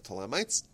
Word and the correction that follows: Ptolemies: or Ptolemies. Properly pronounce it.